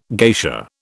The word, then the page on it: geisha: British English